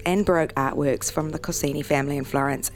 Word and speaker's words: Corsini: She's going to tell us about the gallery's upcoming show, the Corsini Collection. Have I pronounced Corsini right? – Yeah, that's exactly right.